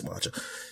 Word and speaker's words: Samardzija: I say his name right. I think it's Samardzija.